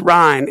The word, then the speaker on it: Ryne: And once again, it's easy to get this screwed up because with the Southern accent, it almost sounds like my name's Ryne, like Ryan, but it's Ryne. It's R-Y-N-E.